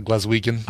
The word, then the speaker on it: Glaswegian: So Claire says I don't think she's Glaswegian. Is that how you say it? Like someone from Glasgow? – Yeah, from Glasgow. Yeah, Glaswegian.